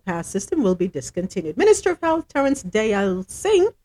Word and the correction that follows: Deyalsingh: Deyalsingh repronounce that. D E Y A L. Dial, say, okay.